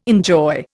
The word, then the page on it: enjoy: Indian